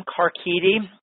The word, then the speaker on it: Carchidi: Well, Jim Carchidi, again, I think I said that right.